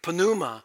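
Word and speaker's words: pneuma (πνεῦμα): and you do pronounce the P in Greek, pneuma, and that means wind or breath or spirit.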